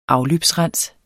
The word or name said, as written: afløbsrens